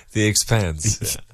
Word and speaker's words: The Expanse: The Expanse.